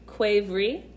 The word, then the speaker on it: kvevri: What they used to brew wine in was, they were called cuivri, and it's spelled K-V-E-V-R-I.